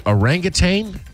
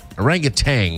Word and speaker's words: orangutan: Orangutan? Orangutan. – Orangutan. – Orangutan. Yeah. There's no G at the end, though.